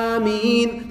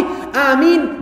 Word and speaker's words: Ameen: So they say, Ameen. That's wrong. That's not correct. So if you want to be with the angels, you want all your sins forgiven, you have to say it properly. How do we say it properly? Ameen. The ah part is only two harakah. What that means? Everybody do this with your hand. Open is one, closing is two.